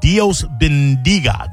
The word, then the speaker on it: Dios Bendiga: Yet Dios Bendiga. Did I say that right? – Sure.